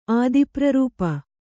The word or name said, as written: ಆದಿಪ್ರರೂಪ